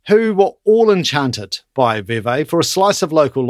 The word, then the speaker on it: Vevey: Nestlé in Vevey. – Yes. Yes, it rhymes.